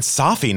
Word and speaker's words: Safi: Flattering is that you said Safi, not Safi, because, you know, everyone says it wrong. You said it right.